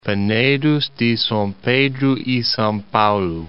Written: Penedos de Sao Pedro e Sao Paulo

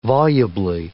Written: volubly

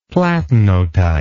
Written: platinotype

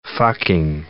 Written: fucking

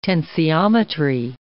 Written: tensiometry

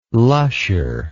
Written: lusher

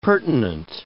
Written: pertinent

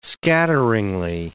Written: scatteringly

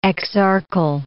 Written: exarchal

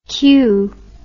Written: Khieu

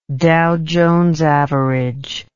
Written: Dow Jones average

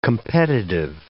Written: competitive

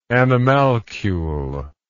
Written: animalculous

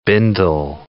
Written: bindle

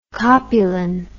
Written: copulin

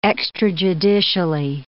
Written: extrajudicially

